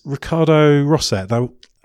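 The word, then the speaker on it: Ricardo Rosset: I'm your host, and my guest is Ricardo Rosso. How good's my pronunciation there? – Yeah, it's great. – I don't think it's great, but it's close enough.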